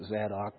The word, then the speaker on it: Zadok: Sons of Zadok. I pronounce it Zadok, but who cares, you know.